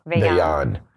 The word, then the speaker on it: Vejen: but they had a game called Vayan. It's spelled Vejen, V-E-J-E-N, and